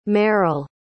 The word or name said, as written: mearl